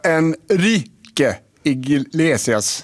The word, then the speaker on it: Enrique Iglesias: Det var Enrique Iglesias, ja.